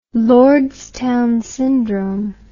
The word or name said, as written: lordstown syndrome